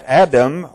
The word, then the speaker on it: Adam: That the waters which came down from above stood and rose up in one heap a great way off at Adam, or Adim. How do you pronounce that? A-D-A-M, pardon me? Adam, okay. It says